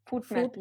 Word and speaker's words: FODMAP: Spricht man das so aus? – Foodmap, Foodmap ja. oder FODMAP? – Foodmap-Ernährung ähm, erwähnen.